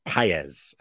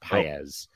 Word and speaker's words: Pages: I think it's spelled Pages, but I think it's Paez. I think it's pronounced Paez.